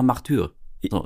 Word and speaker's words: Martyrs: Er gehörte aber auch zur Besetzung eines Films, den er jetzt kürzlich besprochen hat, nämlich Martyrs. Wird das ausgesprochen? Martyrs? – Also uns wurde gesagt, so, wir haben das S nicht hinten rangehangen.